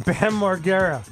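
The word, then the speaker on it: Bam Margera: Ben Margera is 44. – Well, at least you pronounced it right.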